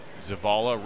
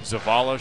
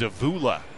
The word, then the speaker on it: Zavala: He says the worst he's heard is Zavula. Don't know where that comes from. There's no U in there. Somehow he's gotten Zavula a couple times.